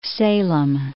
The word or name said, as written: Salem